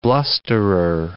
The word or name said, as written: blusterer